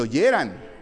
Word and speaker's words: oyeran: Ok, te oyerán. Bueno, ahí se los dejo de tarea, ahí me lo pasan, ¿eh? Sí, lleva acento en la A. No, te oyeran.